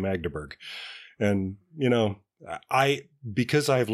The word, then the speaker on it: Magdeburg: Mandeberg Train. – Magdeburg.